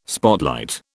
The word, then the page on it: spotlight: British English